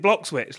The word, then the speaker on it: Bloxwich: It's Bloxwich. You pronounce it Bloxwich, like this.